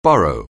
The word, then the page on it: burrow: anglais